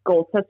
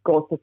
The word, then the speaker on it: Golsa: Well, my Instagram is Golsa Sarabi. My Facebook is Golsa Sarabi Official.